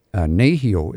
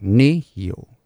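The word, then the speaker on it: nêhiyaw: But if you have three, again, back to the word like nehio, it's sort of on the first one, right? Like nehio.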